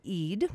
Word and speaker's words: Ede: It's spelled E-D, but pronounced Ede, so that you know.